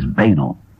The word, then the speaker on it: banal: The Oxford Dictionary says the proper pronunciation is banal.